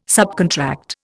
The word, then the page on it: subcontract: Indian